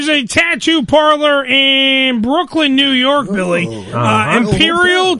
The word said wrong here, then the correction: ephemeral: Tattoo. Imperial. I'm saying this wrong. Ephemeral.